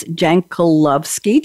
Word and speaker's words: Jankulovski: And then we have Chris Jankulovski.